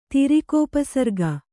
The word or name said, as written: ತಿರಿಕೋಪಸರ್ಗ